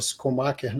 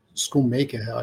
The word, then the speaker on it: Schoonmaker: Pois é, o, o que eu queria chamar a atenção mesmo é que a, a, a Thelma Schumacher, né? Schoonmaker, acho que é como eles pronunciam o nome dela.